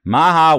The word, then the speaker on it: Maha: Maha, welcome to the podcast.